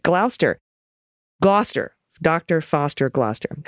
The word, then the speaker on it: Gloucester: Here's a mnemonic nursery rhyme used to teach the generations of English school children how to pronounce Gloucester. Dr. Foster went to Gloucester. Okay, Gloucester.